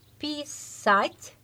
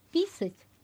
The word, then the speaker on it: писать: Si vous prononcez писать, donc c'est écrire. Mais si vous êtes dans le même mot, vous changez accent tonique et vous dites писать, pardon, ça fait pipi.